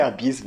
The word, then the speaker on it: abs: Eu falava abs de, de abs de abdômen, a pronúncia é abis, velho.